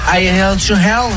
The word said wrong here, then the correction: Highway to Hell: Non mais I Held to Hell, non mais n'importe quoi. – Voilà, Highway to Hell, l'autoroute vers l'enfer.